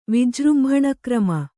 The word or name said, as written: ವಿಜೃಂಭಣಕ್ರಮ